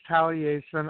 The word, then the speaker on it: Taliesin: The proper way to pronounce it is Taliesin. I chose the name back, you know, oh, God, whenever DJ Conway's Celtic Magic came out back around 90, 91, and I had no idea how to pronounce Welsh, so I always called it Taliesin, and that's what my wife calls me.